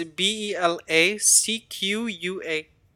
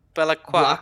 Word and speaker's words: Belacqua: Um, I can't pronounce it. It's B E L A C Q U A. Belacqua. – Belacqua.